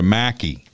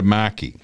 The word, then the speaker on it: Markey: Okay, so then there, and she, by the way, she didn't pronounce his name right. It's Senator Markey. It's Senator Markey.